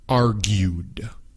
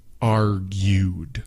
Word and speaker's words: argued: Argued. Argued. Okay, so it's A-R-G-U-E-D. Argued. There's not a separate syllable for the E-D. No es argued. No, it's argued. Argued.